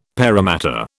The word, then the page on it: Parramatta: British English